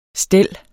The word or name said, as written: stel